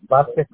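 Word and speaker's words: Vásquez: El mío es Vázquez con S y Z al final.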